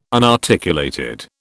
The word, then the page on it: unarticulated: British English